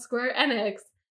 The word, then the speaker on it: Square Enix: And then during the showcase, literally this year, like I was today today years old when I found out that it's pronounced Square Enix. They like they're like, all right, well now we're going to move on to the Square Enix showcase, and I was like, you asshole you have had me wrong.